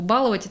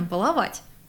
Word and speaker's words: баловать: Всем известно, что баловать – это неправильно. А правильно – баловать.